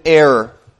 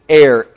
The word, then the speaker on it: heir: Esau was an heir. An heir, H-E-I-R. I said that wrong.